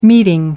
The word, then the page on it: meeting: American English